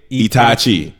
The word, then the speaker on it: Itachi: I sounds like E. Ta sounds like tatas, but once. – Itachi. – Hokage Itachi.